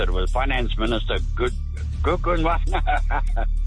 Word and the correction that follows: Godongwana: Aye. If we gave points for pronunciation, you'd be nowhere. Are we in a forgiving mood? I think we're probably on balance going to have to give it to you. Does Enoch Godongwana?